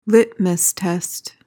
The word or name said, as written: litmus test